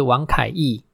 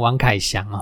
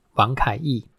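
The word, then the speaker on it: 王凯翊: 那分别是王凯毅哦，我每次都把他的名字念成王凯翔哦。那个这个是毅啊哈，一个立字旁，在一个羽毛的羽哦，王凯毅。因为我们还有另外一位选手，他的名字是杨凯翔，那所以我就把常常把他们两个名字搞混哦。好，这个是王凯毅啊，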